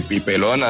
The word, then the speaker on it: Pipelón: Entonces yo le puse las tildes en todas las sílabas para que lo pronunciaran bien. Entonces quedó así, Pipelón, así con tildes en todas las sílabas y todo en mayúscula, porque...